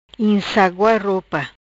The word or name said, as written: Insagua Ropa